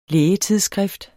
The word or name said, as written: lægetidsskrift